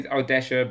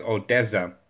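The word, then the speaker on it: Odesza: I say Odesha because that's how I choose to pronounce it, but I know that it's also pronounced as Odessa.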